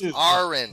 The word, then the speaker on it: Aron: You can't pronounce his name correctly for the 50 times. Aron.